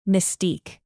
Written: mystique

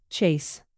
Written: chase

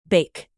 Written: bake

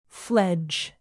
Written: fledge

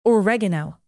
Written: oregano

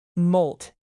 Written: moult

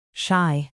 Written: shy

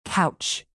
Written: couch

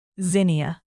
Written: zinnia

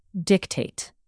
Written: dictate

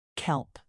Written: kelp

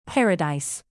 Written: paradise

